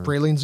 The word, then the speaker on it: pralines: Pralines and cream? – Pralines and cream. – Really? – Yes. – Got them the first time.